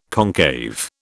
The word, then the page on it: concave: British English